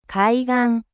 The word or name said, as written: 海岸